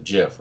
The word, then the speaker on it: GIF: So you're using a hard G, so let me guess. You're one of those people that says GIF instead of JIF, right? – Well, yes, I say it correctly.